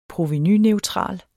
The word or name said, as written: provenuneutral